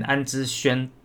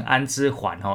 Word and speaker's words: 安之儇: 次要讲的是台钢雄鹰的啦啦队 Winstar 呢，他们在上周呢宣布他们延揽的韩国国民啦啦队女神安之轩。要引进韩职拉队经验设计及指导整体培训课程，打造专业拉拉队培训哈。呃，先跟大家这个算是更正一下我自己发音哈，因为我以前在念安之轩的轩，我都把它念成安之环哈，有边读边没边念中间。